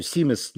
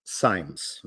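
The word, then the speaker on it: Simes: И он, ну, Симес неудобно было произносить, он стал Саймс, ну, такая американская фамилия.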